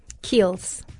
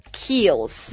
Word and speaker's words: Kiehl's: 它的拼法是 K-I-E-H-L-E-P-S. – Kiehl's. – Kiehl's.